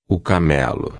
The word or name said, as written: camelo